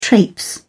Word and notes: traipse: British English

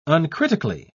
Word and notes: uncritically: British English

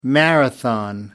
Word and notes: marathon: American English

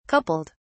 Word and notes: coupled: British English

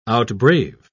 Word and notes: outbrave: British English

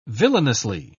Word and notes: villainously: British English

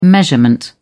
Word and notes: measurement: British English

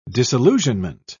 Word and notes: disillusionment: British English

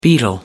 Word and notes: beetle: American English

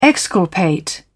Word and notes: exculpate: British English

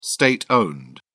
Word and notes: state owned: British English

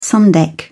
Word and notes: sun deck: British English

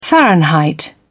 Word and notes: fahrenheit: British English

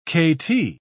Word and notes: kt: British English

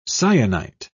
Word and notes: cyanite: British English

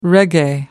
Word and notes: reggae: American English

